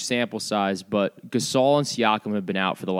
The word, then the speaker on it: Siakam: Norman Powell, Marc Gasol, and Pascal Siakam are all out.